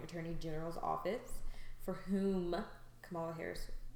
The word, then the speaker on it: Kamala Harris: I've done some research on this Kamala Harris lady. I don't know if I'm saying her name right. And honestly, Kamala Harris.